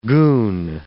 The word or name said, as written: goon